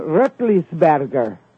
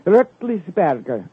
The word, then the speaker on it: Roethlisberger: Ben Roethlisberger. His family's from Switzerland. Roethlisberger. Okay? That's how they pronounce it there.